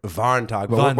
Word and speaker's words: Warntag: But I mean, pronounce it in Germany, Warntag.